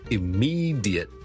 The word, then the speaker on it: immediate: Immediate, I-M-M-E-D-I-A-T-E, 立刻立即。咁有个听众问萧叔 ,diet 呢应该点样读得好啲？正常呢我哋如果係好快咁读呢，我哋可能会读成 jit 嘅啫 ,jit, jit, jit, 好似 J-I-T 咁嚟读 ,jit, jit, jit, 或者用一个好弱嘅 schwa 音 ,jit, jit, jit, 或者 jit, 对啦。